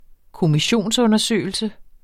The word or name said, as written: kommissionsundersøgelse